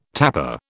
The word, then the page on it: tapa: British English